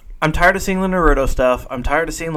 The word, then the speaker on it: Naruto: It's pronounced Naruto.